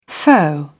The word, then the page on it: foe: British English